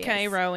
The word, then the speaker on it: Cairo: It's pronounced Cairo, and you know that.